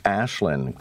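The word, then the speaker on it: Aislinn: Okay, so Green Party has won a second seat, this one in Kitchener, and we finally determined that it's pronounced Ashlyn Clancy, but it was a pretty good thumping, and the NDP and the Liberals have to be wondering what's not working for them.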